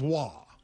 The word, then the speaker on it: Roy: There's no R. – Why do you keep throwing in an R? – But there's an R in the name, though, right? – Yeah, R-O-Y, but it's pronounced Wah.